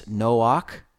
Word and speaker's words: Noach: And then Genesis 5, 28 through 31, Lamech lives and he begets a son and calls his name Noach. Now, we know him as Noah in the King James Version, but Strong's Concordance, number 5146, pronounces it as Noach, and his name means rest.